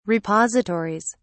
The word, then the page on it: repositories: British English